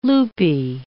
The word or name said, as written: loopy